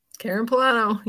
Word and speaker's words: Pullano: Karen Pullano.